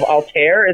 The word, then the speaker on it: Altair: Like my one of my favorite stories was Large Breasted Ninjas of Altair. Is that how you pronounce it? – Yes, yes.